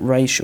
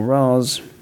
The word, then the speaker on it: Ra's: Not to mention they couldn't pronounce his name right, Ra's or Ra's, up to you.